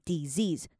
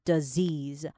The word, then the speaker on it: disease: 那后面来看一下这个 disease 哈，它不是念 disease，它是的 disease 哈，两个 s 都是 z 的音。